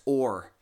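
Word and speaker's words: ore (אור): It's literally pronounced ore, like iron ore, or, and, but, and or, O-R. That's how it's pronounced.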